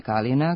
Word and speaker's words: Kalina: A žensko ime Kalina, kao i naziv biljke po kojoj je nastalo ime, naglašava se samo na prvom slogu, kao što smo govorili u jednoj od naših emisija.